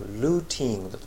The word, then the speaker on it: looting: 引っかかるところは最後のルーティングだと思いますね。しっかり発音するとルーティング、これはイギリス人はですからそういうふうに発音するんですけども、アメリカ人はですね、単語のケツではなくて途中に T があると D になるんです。これ